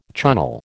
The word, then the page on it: Chunnel: American English